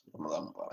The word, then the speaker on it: Ramadan Mubarak: Yes, Ramadan Mubarak. Did I say it correct? – Yes, you said it correctly. You actually pronounced it very correctly.